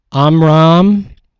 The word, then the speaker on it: Amram: Amram, A-M-R-A-M, that's his dad. Jochebed, which is spelled J-O-C-H-E-B-E-D, that's his mom.